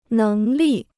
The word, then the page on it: 能力: néng lì